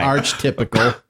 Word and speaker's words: archetypical: That's not even the right wrong pronunciation. – The right wrong... – Archetype. – Archetype. – Archetypical.